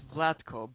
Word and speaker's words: Vlatko: Well, it's like that. Yeah, it's Vlatko.